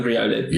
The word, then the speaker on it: Riolu: He finds this album in the bin, and he's got like in, in some of the early ones, he's got a uh, I never know how to pronounce this Pokemon. Riolu, Riolu, Riolu, Riolu, Riolu. Riolu. – Yeah. We're going to go with Riolu.